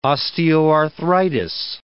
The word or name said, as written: osteoarthritis